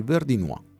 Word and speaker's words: Verdinois: Verdinois è perfetto, perché la S finale non si pronuncia, o I e UA, quindi Verdinois è perfetto, vedi? – Ok.